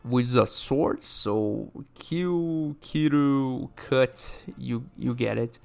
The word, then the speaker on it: Kiru: So for instance, right, before I even start, Akame ga Kill in the title is a play on words, because in Japanese it's Akame ga Kiru. Kiru is the English way to say kill, and also the verb to cut.